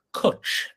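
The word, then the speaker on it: Cwtch: Kathy has a place in Wales called, let me pronounce this properly, Cutch. It's called Cutch Dog Adventure Park. Cutch is a Welsh word that means cuddle, believe it or not.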